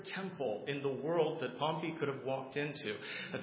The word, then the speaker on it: Pompey: After Pompey, I believe is the better pronunciation,